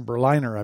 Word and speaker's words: Berliner: And when a senior editor, Yuri Berliner, or Berliner, Berliner, I believe is how it's pronounced. It's like Berlin with an ER at the end.